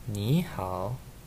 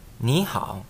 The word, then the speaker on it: 你好: 你好。 – 你好。